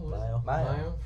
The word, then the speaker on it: Mio: Mayo.